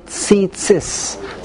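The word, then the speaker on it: tzitzis (ציצית): He told me that among us, among the crowds that at least I'm familiar with, the way it's taught is to say tzitzis.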